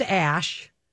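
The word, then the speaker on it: æ: Okay, so that is pronounced ash, that AE ligature. – Okay. – That's pronounced ash.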